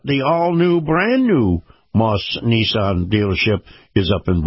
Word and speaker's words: Maus: And by the way, this portion of the program, my conversation with retired Lieutenant Colonel Kevin Wright, brought to you by Moss Nissan. Moss is spelled M-A-U-S.